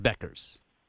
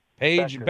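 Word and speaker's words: Bueckers: Hey, that UConn player is Paige Buchers. – Paige Buchers. – It's pronounced Beckers. – Paige Beckers. – Beckers.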